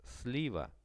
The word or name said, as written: sliva